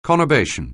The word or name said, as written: conurbation